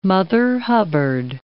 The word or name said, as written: Mother Hubbard